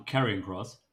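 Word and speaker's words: Karrion Kross: Carion Cross. Habe ich das richtig ausgesprochen? – Um, ähm, Carrion Cross.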